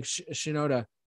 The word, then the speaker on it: Shinoda: All the lyrics were written by Chester Bennington and Mike Shinoda.